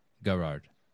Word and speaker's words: Garard: Am I pronouncing your last name right? – Garard. Yes.